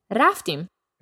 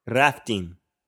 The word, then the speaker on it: رفتیم: رفتیم. – رفتیم.